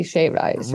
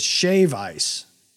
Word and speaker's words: shave ice: It wouldn't be shaved ice, right? – Right. Yeah. Okay. I'm glad you said it right. I think you said it wrong the first time. It is shave ice.